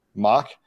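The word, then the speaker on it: Mauch: Cody Mau, not actually sure how his last name is pronounced. – I think it's Mock, right? – Mock. Both of my guesses were wrong.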